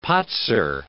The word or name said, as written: patzer